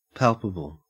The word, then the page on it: palpable: Australian English